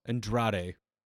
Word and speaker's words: Andrade: But yeah, Johnny Gargano, I guess, was in a match against I always say his first name wrong. – Andrade Almas? – Andrade. – Right? – I feel like I add a letter.